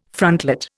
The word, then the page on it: frontlet: Indian